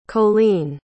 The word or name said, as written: colene